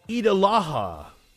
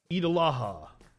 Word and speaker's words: Eid al-Adha: Eid al-Adha. I'm still fucking up. – Eid al-Adha.